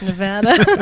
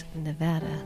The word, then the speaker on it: Nevada: I've seen 100 YouTubes on it, I swear, that it's pronounced Nevada, not Nevada.